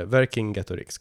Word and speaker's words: Vercingetorix: Eh, Vercingatorix hette han. – Vercingatorix? – Eller Ver Vercingetorix, Vercingetorix, lite olika stavningar och uttalningar. Men eh, Vercingetorix kommer jag säga.